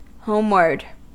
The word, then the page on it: homeward: American English